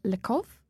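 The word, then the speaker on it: Le Kov: Speaking of doing something differently, next up we've got Gweno and her new album on Heavenly, which is called Lakov, I think it's pronounced.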